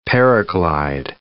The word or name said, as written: paraglide